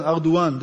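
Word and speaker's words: Erdoğan: Erdogan, for some reason, call him Erdogan, Erdogan, that's the right pronunciation,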